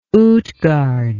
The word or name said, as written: Utgard